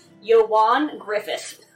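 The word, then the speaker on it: Ioan Gruffudd: I was just looking at his trivia on IMDb, and it says his name is pronounced Eoin Gruffudd.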